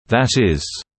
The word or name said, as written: that is,